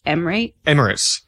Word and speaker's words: Emirates: Emirates.